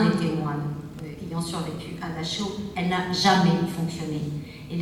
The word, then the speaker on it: Dachau: Dachau, échafaud, chaud, à condition bien entendu qu'on croit comme lui que ça se prononce Dachau, parce que les germanophones savent que ça se prononce Dachau, et qu'on ne peut pas faire le jeu de rime intérieure que je viens de citer.